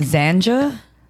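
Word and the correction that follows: Xanga: It's pronounced Zanga, bro. – You said J, though. – Did I? Fuck. I'm high. Okay. – G. So X-A-N-G-A.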